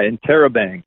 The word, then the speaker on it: Interrobang: Interrobang. Is that how you say it? – Yeah, Interrobang.